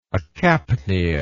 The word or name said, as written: acapnia